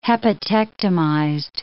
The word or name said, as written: hepatectomized